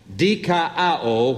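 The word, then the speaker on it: dikaio (δικαιόω): The Greek word for justify is a word which sounds like this, dikaio, which is spelled D-I-K-A-I-O-O.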